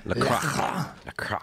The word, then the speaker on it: LaCroix: LaCroix.